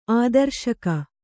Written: ಆದರ್ಶಕ

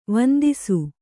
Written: ವಂದಿಸು

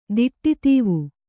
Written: ದಿಟ್ಟಿತೀವು

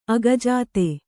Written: ಅಗಜಾತೆ

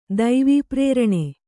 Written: ದೈವಪ್ರೇರಣೆ

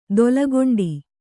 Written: ತಲೆಗಂಟು

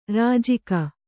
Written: ರಾಜಿಕೆ

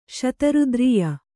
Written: ಶತರುದ್ರೀಯ

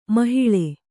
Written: ಮೊಹಲ್ಲಾ

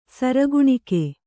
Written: ಸರಗುಣಿಕೆ

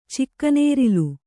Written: ಚಿಕ್ಕನೇರಿಲು

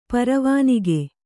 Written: ಪರವಾನಗಿ

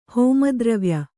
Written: ಹೋಮದ್ರವ್ಯ

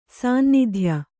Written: ಸಾನ್ನಿಧ್ಯ

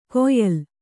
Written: ಕೊಯ್ಯಲ್

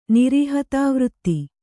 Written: ನಿರೀಹತಾವೃತ್ತಿ

